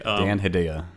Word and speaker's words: Dan Hedaya: Dan Hedaya, right? Yeah. That's how you pronounce him.